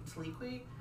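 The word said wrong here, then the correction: Twi'lek: Don't. – Tylik.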